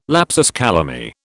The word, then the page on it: lapsus calami: British English